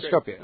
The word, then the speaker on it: Skopje: Yeah, Skopje. – Okay. That's it. – After Skopje in Macedonia, where we visited with some of your, are they relatives or they're? – Yeah, I'll clarify.